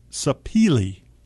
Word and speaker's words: Sapele: I'm sorry, Sapele, okay, is how it's pronounced.